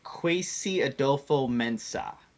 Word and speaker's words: Kwesi Adofo-Mensah: It's Kwesi Adofo-Mensah is how you pronounce Brown's Vice President of Football Operations.